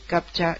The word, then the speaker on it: captcha: Porque primero hay que decir que captcha, y así haciendo un alarde un poco de la pronunciación inglesa, es Completely Automated Public Turing Test to Tell Computers and Humans Apart.